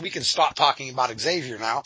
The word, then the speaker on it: Xavier: definitely it's Xavier. It's not Xavier. If you wanted to spell it that way, then you'd put a Z on the front of it.